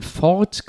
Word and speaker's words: Fort: Ähm, bei der er in das Haus der Kunst ein Fort gebaut hat. Ähm, also ein For, halt, Ach, ein for, okay. The T is Silent, ja.